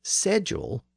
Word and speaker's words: schedule: In case you forgot, the word was apparently pronounced as sedule throughout Middle English and Early Modern English. It was originally spelled S-E-D-U-L-E or C-E-D-U-L-E.